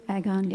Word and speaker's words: Agon: And then you describe Agon, am I saying it correctly? – Agon, yep.